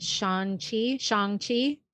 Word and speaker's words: Shang-Chi: So did that, saw, I'm going to mispronounce it. Is it Shang-Chi? – Oh, yeah. – Yeah, I think that's correct.